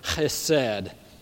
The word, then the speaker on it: chesed: I can't get it far enough back in the throat. Chesed. It's got that kind of, sounds like you're clearing your throat a little bit.